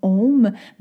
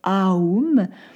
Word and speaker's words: Om: Donc, d'un point de vue purement physiologique, le son OM, on l'écrit OM, mais à l'audio, quand on le prononce, on devrait plutôt le prononcer non pas comme OM, mais AUM.